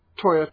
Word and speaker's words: Tora: Now, if you've seen Toya, Toya, Toya, or Tora, Tora, Tora, it's spelt us, but Japanese pronounce it Toya, Toya, Toya.